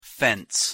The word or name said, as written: fence